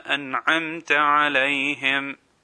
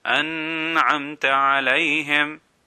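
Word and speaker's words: أَنْعَمْتَ: If we stretch it, then this is an incorrect pronunciation. An incorrect pronunciation will be an'amta alayhim. This is incorrect.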